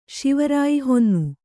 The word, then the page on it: ಶಿವರಾಯಿಹೊನ್ನು: kannada